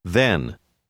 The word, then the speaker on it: then: Les lettres TH ont le même son que dans le mot 8, mais ce mot rime avec ten. Then.